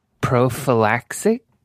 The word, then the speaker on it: prophylaxis: Wait, how do you say it? Prophylaxis. Let me see. Prophylactic. Oh, pre-exposure oh, prophylaxis. Okay, so uh, it's and also like yeah, it basically acts like a big condom.